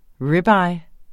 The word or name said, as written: ribeye